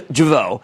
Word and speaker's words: Jeuveau: It's a medical aesthetics play that just launched their first product, which is Jeuveau, which rhymes with hello.